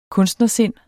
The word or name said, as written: kunstnersind